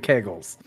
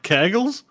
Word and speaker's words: kegel: Wait, do you bagel kegels? – Kegels. Kegel. That's how you pronounce it, kegel. – Is it kegel? – That's how you say it. – I thought it was kegels.